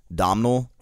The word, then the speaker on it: Domhnall: Yeah, it's D-O-M-H, this became spelling on podcast, D-O-M-H-N-A-L-L, Domhnall? – Domhnall. – Domhnall? – Domhnall. – I think the H is silent, I don't know.